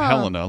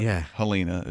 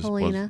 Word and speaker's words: Helena: Not Helena, like Montana, but Helena is Helena.